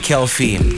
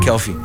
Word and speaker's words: Kelphy: This is how it's spelled. K-E-L-P-H-Y, Kelfi. Like Philadelphia, right? Yeah, Kelfi.